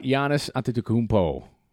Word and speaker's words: Giannis Antetokounmpo: I would have thought Giannis Antetokounmpo. – Yeah, did I say that correctly? – Yes. Yeah.